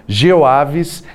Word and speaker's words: GeoAves: Está com a gente a Joaninha Sanches, coordenadora do projeto GeoAves ela se descreve como uma mulher branca com cabelos grisalhos e curtos. Pronunciei certo, professora? É Geoaves mesmo? Sim. É isso? Tá certinho, então.